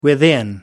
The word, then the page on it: within: American English